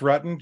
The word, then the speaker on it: Rutten: And it is pronounced Rutten, just like my mom told me, with when you have one vowel and two consonants, it's a short. So it's like Rutten like button, but that's okay.